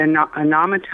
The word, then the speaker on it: anemometer: Even your aside about the wind anemometer, how do you say that? Anemometer. Even conversation about that, that's the first step.